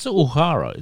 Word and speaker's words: Uhura: Uhura? Uhura? Is that how you say it? Uhura? – It's Uhura, isn't it? Yeah.